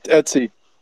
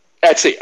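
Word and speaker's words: etc: I was at Etsy. – Etsy. Okay, that is Etsy, though. – But yeah, I've but called that, it Etsy.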